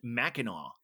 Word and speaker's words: Mackinac: That's a Michigan thing, and it's pronounced Mackinaw. – I'm so Buffalo. Yeah. Mackinac.